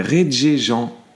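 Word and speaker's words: Regé-Jean: Ensuite, on a René Jean Page. Je pense que je le prononce vraiment pas bien. Non, c'est Reggie Jean Page.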